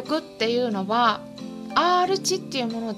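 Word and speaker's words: R値: で R 値って読むん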